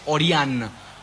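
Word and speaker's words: Ørjan: Hagam, el nombre de Nyland, que se pronuncia Nyland y el nombre es Orian. Como si fuera Sí. – Oriana en el nombre de chica.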